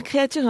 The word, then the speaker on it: Creature: Alors vous avez raison Daniel, il s'agit de Creature parce que c'est en anglais, mais pour faire simple on va le prononcer à la française, c'est ce qu'on a décidé en zone critique pas plus tard que vendredi dernier. Donc voilà, alors Creature